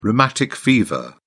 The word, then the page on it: rheumatic fever: British English